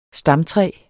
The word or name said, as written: stamtræ